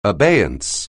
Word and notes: abeyance: anglais

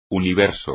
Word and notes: universo: espagnol

